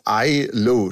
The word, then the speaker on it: Islay: Man sagt eigentlich, habe ich mich jetzt extra erkundigt, Islay, schreibt man eigentlich Islay.